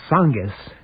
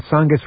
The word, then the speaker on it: Tsongas: Anyway, one of the more heated arguments came when Bill Clinton said that Paul Tsongas, besides having a silent T to start his name, well, if he were president, he would allow construction of hundreds of nuclear power plants.